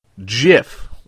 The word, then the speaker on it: GIF: GIF or a JIF. He said there's just one word for it, and it's pronounced, drum roll please, JIF, like J-I-F.